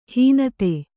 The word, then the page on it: ಹೀನತೆ: hīnate